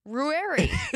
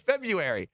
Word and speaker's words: February: Ruary. – No, it's February. – No, it's the F-E-B-R-U-A-R-Y.